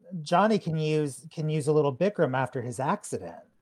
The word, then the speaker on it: Bikram: Is it Bikram or Burkram? How did I say it? – Bikram. Bikram yoga.